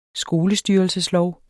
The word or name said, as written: skolestyrelseslov